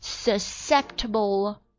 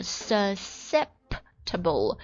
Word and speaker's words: susceptible: Diesel exhaust is particularly aggravating to many susceptible individuals. 注意一下这个 susceptible， susceptible 第二个 s 好像是不发音的，或者它跟这个 c 放在一起发音呢。